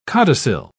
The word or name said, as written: codicil